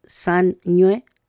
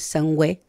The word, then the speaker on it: sañué: Ahí también, sañué, que es como suena, pero se escribe con la letra ñ y la W, que ese sonido, ¿cierto?, que suena como una doble N, pero que no es una doble N. Entonces, no es sangüe, sería sañué.